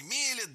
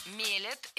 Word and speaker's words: мелят: Уже много лет у нас в стране продаются вот эти бытовые кофемашины, которые сами мелят, делают эспрессо. – Мелят – это неверно. Правильно, мелят, мелит, мелим, мелишь. Ударение в этом слове всегда стоит за корнем, а корень остается безударным.